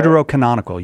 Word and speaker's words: Deuterocanonical: Was the, as you guys call them, Deuterocanonical? I think that's how you say it. – Deuterocanonical, yes. Yes.